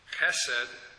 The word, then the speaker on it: chesed: You will also find it spelled, and I mentioned this in the footnote of your notes, chesed, C-H-E-C-E-D, in Strong's Concordance, and some spell it as a transliteration, C-H-E-S-E-D. But it should really be pronounced based on the Hebrew alphabet and the sounds of the letters, chesed, like the C-H in German, as we see at the end of the composer's name, Bach.